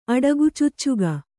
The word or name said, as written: ಅಡಗುಚುಚ್ಚುಗ